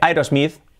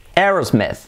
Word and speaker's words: Aerosmith: Pues no se dice Aerosmith, se dice Aerosmith. Aerosmith. Meth. La última I no es una I, es una E. Es una I, pero se pronuncia como una E.